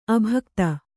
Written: ಅಭಿಗೀತ

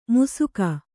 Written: ಮುಸುಕ

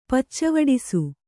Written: ಪಚ್ಚವಡಿಸು

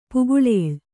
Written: ಪುಗುಳೇಳ್

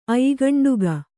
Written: ಅಯ್ಗಂಡುಗ

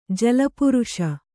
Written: ಜಲಪುರುಷ

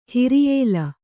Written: ಹಿರಿಯೇಲ

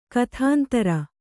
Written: ಕುತಂತ್ರಿ

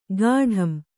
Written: ಗಾಢಂ

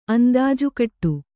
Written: ಅಂದಾಜುಕಟ್ಟು

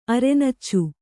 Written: ಅರೆನಚ್ಚು

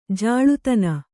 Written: ಜಳ್ಳುತೆನೆ

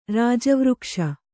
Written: ರಾಜವೃಕ್ಷ

